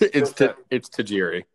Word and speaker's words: Tajiri: Tajiri. I don't know how to pronounce that bastard's name. – No, it's 100%. It's Tajiri. – It's the one who always. – It's Tajiri.